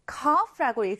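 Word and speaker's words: cough: 이 기침은 cough라고 읽습니다. C-O-U-G-H인데 F 발음이 난다는 점을 주의해 주세요.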